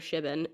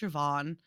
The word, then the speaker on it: Siobhan: I think when I was reading it, I called her Shibin, and I'm like, that's a stupid name. – Yeah, I was like, Siobhan? What kind of name? No, it's pronounced Siobhan.